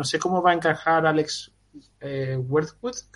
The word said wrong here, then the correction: Leatherwood: si lo pronuncio bien, Leatherwood y, y, Tevin Jenkins en la formación titular este año en Chicago.